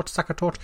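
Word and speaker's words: Sachertorte: Sakatort.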